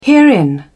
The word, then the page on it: herein: British English